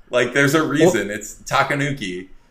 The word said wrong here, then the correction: tanuki: There's the raccoon suit and there's a tanuki suit.